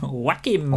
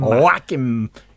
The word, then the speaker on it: Joaquin: Joaquin. – Joaquin to you and I, but Joaquin.